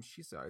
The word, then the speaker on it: Shisa: In fact, the name Shisa is actually the Ryukyuan pronunciation of their Chinese name Shishi.